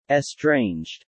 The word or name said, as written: estranged